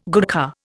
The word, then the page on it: Gurkha: Indian